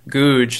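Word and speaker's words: Gouge: If you go to their writings, for example, one of the Westminster divines was named William Googe. That's how I say it, but I know that it could be a different pronunciations. But it's G-O-U-G-E, and he wrote this really famous book.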